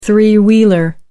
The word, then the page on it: three wheeler: American English